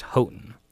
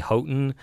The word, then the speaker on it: Houghton: I've actually spoken with Bradley Houghton, who's the great-grandson of William and Florence Houghton, and he stated that it is pronounced Houghton.